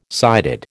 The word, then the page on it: sighted: American English